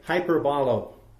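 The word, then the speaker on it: hyperbolo (ὑπερβολή): Now, the more we understand, now the word exceedingly here is found toward the bottom, that last definition, and the Greek pronounces it different than we call it a hyperbole. They say hyperbolo, now, or hyperbole. Now, what's the word parable?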